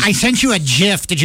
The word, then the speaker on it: GIF: What about GIF? – Oh, that's always been GIF. – Hargy. – GIF. No, GIF. – GIF. – Hargy. – Yeah, not a J.